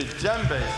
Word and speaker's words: djembe: Or if I want to send a message a long distance, I would use a djembe. Say djembe. – Djembe. – It is spelled D-J-E-M-B-E with D sound. D-J-E-M-B-E.